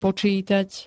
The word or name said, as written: počítať